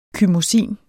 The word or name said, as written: chymosin